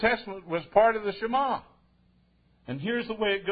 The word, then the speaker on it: Shema: And it's sometimes pronounced Shema, but it's really Shema.